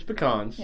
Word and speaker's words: pecans: Well, no, it's pecans. Yeah, it's pecans. That's the way you pronounce it.